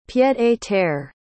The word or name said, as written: pied-à-terre